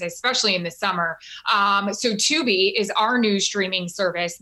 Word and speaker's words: Tubi: Tubi.